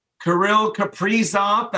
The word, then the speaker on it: Kirill Kaprizov: Kirill Kaprizov, that's how he wants his name pronounced.